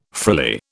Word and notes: frilly: British English

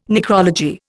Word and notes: necrology: Indian

